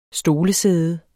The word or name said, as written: stolesæde